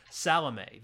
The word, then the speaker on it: Salome: And at the time, she was doing a play on Broadway, and it was, I'm probably going to mispronounce this, Salome, the Oscar Wilde. – No, that's right. – She was playing Salome.